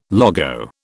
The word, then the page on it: logo: British English